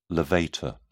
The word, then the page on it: levator: British English